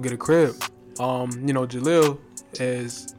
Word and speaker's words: Jahleel: Well, her name is spelled Jaleel, so it's like J-A-H-L-E-E-L. So Jaleel.